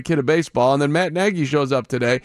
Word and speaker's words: Nagy: He made a point, because everybody calls him Matt Nagy because it's N-A-G-Y, but it's Matt Nagy. N-E-H-G-Y is how it's pronounced. I mean, he really went over the top to say, here's Matt Nagy.